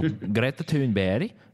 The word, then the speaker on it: Greta Thunberg: So Greta Thunberg, that's the correct Swedish pronunciation, she'll be there.